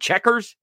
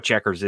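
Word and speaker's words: Chequers: And I think you would pronounce it checkers, like the game checkers, right? Yeah, it's C-H-E-Q-U-E-R-S.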